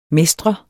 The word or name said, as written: mestre